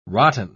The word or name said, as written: rotten